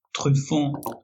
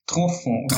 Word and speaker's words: Truffaut: Truffon. – Truffon. Truffaut.